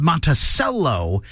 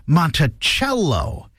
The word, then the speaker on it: Monticello: It is funny because in Minnesota, that town is called Monticello, but in Charlottesville, Virginia, the home of Thomas Jefferson is Monticello.